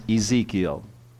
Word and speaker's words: Ezekiel: Now, as you can see in your notes, we don't use the Hebrew pronunciation of it, because then we would all have to wear masks or something. But it's, technically it's Yechzel, and that's really awkward to say. So we use the Greek pronunciation of it from the Septuagint.